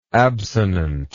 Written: absonant

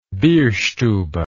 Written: bierstube

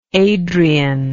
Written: Adrienne

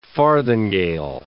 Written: farthingale

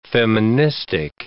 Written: feministic

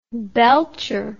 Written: belcher

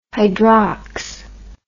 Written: hydrox

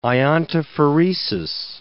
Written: iontophoresis

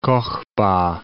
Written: Kokhba

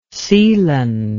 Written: seeland

